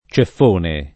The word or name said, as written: ceffone